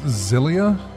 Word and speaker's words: Xillia: I guess it's pronounced Xillia? X-I-L-L-I-A? – Yeah, usually something like that. It does have a Z sound to the front.